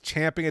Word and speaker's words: champing: And that's how it is pronounced, by the way. Pet peeve of mine. It's not chomping at the bit. It's champing at the bit.